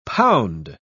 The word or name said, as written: pound